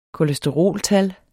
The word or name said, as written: kolesteroltal